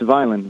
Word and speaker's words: Vineland: Did I say that right? – It's Vineland.